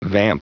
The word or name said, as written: vamp